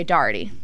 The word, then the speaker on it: Doherty: So, when I say my last name, I say Doherty. – Okay. – But in upstate... – Doherty. – Doherty. – Like, Doherty. – Doherty. – Doherty. – This will be the whole episode, just saying my name. – Emily Doherty. – But no, so... That's how I say it, but I'm from upstate New York in Syracuse, and everyone in Syracuse says Doherty.